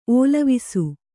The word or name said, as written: ಓಲವಿಸು